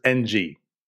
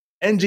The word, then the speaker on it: Ng: NG is pronounced like ing every time I've heard it. We just have comment after comment about everyone's interpretation of how to say her name. So there's a soft N sound. So Inwin. That's how I was taught to pronounce it. Or like Brandon Inge. It was a win-win situation.